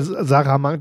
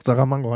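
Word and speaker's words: Saramago: Ähm, der Roman dazu heißt Der Doppelgänger von José Samarango. Äh, Sarah Mango, doch, Sarah Mango heißt der Typ, glaube ich.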